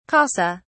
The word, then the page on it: casa: British English